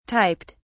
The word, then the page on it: typed: British English